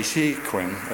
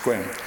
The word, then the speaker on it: Sequim: Now, Squim is spelled, for you Minnesotans, it's S-E-Q. We don't say Sequim, it's Squim.